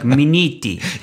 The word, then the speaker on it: Minniti: Well, yeah, but only with that Italian accent, like Minniti.